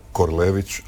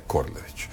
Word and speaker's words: Korlević: Ali hrvatski standard ima jedno pravilo koje kaže da imena treba izgovarati onako kako ih izgovaraju njihovi korisnici. I zato je Korado Korlević, a ne Korlević.